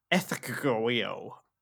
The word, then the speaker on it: Ecgtheow: Um, right, before I say it, it's spelled E C G T H E O W. Ethegio.